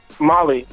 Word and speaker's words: Mali: How would you pronounce M-A-L-I space J? Would you say that's Mally or Molly? – Molly, like the country.